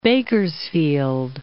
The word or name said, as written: Bakersfield